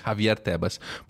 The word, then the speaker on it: Javier Tebas: o Javier Tebas. Exatamente. Acertei ah, a pronúncia? Isso, é perfeitamente. Javier Tebas.